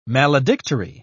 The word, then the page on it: maledictory: British English